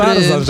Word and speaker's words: Tarzan: Tarzan, że niby RZ, ale nie czyta się jako Ży, tylko Rzy.